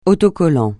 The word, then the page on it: autocollant: francais